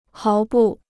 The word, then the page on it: 毫不: háo bù